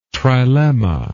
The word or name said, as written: trilemma